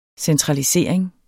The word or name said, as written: centralisering